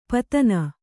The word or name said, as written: ಪತನ